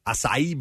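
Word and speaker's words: açaí: We also, and we discovered it here on the air, and the proper way to say it, acai bowls.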